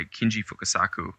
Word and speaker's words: Kinji Fukasaku: So the movies are nothing to do with the author, but apparently it's a, the movie is directed by Kinji Fukasaku, who was an older...